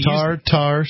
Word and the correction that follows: Tardar: Sauce. – Tardar. – It's spelled Tardar. T-A-R-D-A-R.